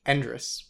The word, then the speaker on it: Endres: Endress. – Endress. – Yes. – I was always thinking it was like Andres. – Yeah, everybody does that.